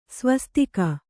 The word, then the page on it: ಸ್ವಸ್ತಿಕೆ: svastike